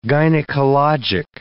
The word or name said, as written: gynecologic